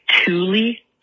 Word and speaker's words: Tooele: It's spelled like Tooele, T-O-O-L-E, and it's Tooele, because we're weird here.